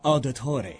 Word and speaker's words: Auditore: It's pronounced Auditore. – Auditore. – Auditore. – Auditore. Auditore. Auditore. It's Auditore. I meant to say Auditore.